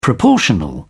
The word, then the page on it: proportional: British English